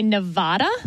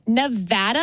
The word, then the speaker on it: Nevada: because a lot of politicians would say Nevada. – Wrong. – And that is wrong. – It is Nevada. And I had to train myself to say Nevapple so I could pronounce the A right.